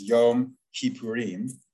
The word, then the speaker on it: Yom Kippurim: So just to explain that the Hebrew there, the Yom Kippur in the alternate Hebrew pronunciation is Yom Kippurim.